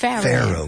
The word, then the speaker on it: Pharaoh: How do you say Pharaoh? – The Pharaoh. – Pharaoh. – Like fair and unfair. – Pharaoh. Mm-hmm. – Pharaoh. – Pharaoh. It's como hada, pero masculino. – Fairy and Pharaoh.